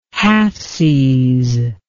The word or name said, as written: halfsies